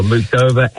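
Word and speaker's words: Muchová: Muchova and Noskova.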